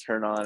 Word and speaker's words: Hernan: And it's funny, my daughter kept on, I'm from England, as everybody knows, and I kept on saying Hernan, but it's Hernan.